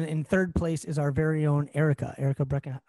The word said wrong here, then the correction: Breckheimer: I say her name wrong. Like, luckily, I did not say it wrong on the Black Belt Spectacular. So Erica Breckheimer, right? I add an N in there.